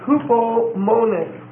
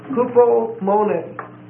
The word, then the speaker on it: hupomone: Okay, the next word is hupomone. Hupomone. H-U-P-O-M-O-N-E. Hupomone. And it's a long E. H-U-P-O-M-O-N-E. Hupomone. Comes from two words, hupo, H-U-P-O, which means under, and then meno, same word that we just looked at, M-E-N-O, which means to abide.